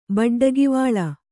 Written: ಬಡ್ಡಗಿವಾಳ

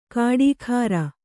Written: ಕಾಡೀಖಾರ

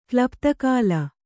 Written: ಕ್ಲುಪ್ತಕಾಲ

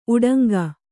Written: ಉಡಂಗ

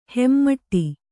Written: ಹೆಮ್ಮಾಡಿ

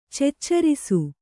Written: ಚೆಚ್ಚರಿಸು

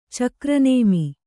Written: ಚಕ್ರನೇಮಿ

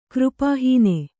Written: ಕೃಪಾಹೀನ

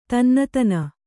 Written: ತನ್ನತನ

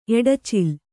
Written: ಎಡಚಿಲ್

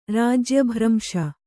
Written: ರಾಜ್ಯಭ್ರಂಶ

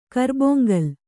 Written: ಕರ್ಬೊಂಗಲ್

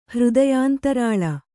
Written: ಹೃದಯಾಂತರಾಳ

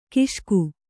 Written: ಘೋಷಕ

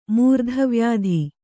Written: ಮೂರ್ಧವ್ಯಾಧಿ

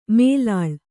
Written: ಮೇಲಾಳ್

